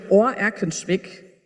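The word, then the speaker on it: Oer-Erkenschwick: Ich komme gerade just heute Morgen aus dem schönen Ohr Erkenschwick. Ja, bitte nicht Öhr aussprechen, so also mit diesem OE.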